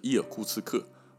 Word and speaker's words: 伊尔库茨克: 从地图上面来看的话，东边哦是乌兰乌德，乌兰乌德，左边就是西边啊，是伊尔库茨克，俄文发音叫做伊尔库茨克。这两个城市刚好把贝加尔湖夹在中间，所以去到贝加尔湖呢，离最近的城市啊，理论上应该是伊尔库茨克。